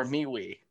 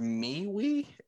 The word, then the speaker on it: MeWe: I believe it's pronounced MeWe. It's MeWe. And the M and the W are capitalized.